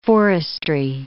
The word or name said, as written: forestry